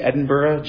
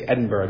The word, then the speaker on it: Edinburgh: By the way, when you see Edinburgh, G-H at the end, you say Edinburgh, not Edinburgh. Edinburgh, that's how they say it.